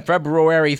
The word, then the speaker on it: February: And finally, on February 3rd, and by the way, February has two R's and both are pronounced,